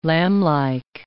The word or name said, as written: lamblike